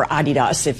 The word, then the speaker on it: Adidas: Do we know how Adidas is responding, or Adidas, if you're, you know, dedicated to the German pronunciation, how they're responding to the World Cup finals and the fact that two of their teams were facing off against one another? – Yeah, that's a great question.